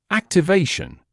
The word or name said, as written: activation